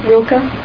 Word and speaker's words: Rilke: Rilke.